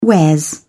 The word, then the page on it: wares: British English